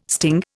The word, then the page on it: sting: Indian